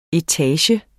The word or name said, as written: etage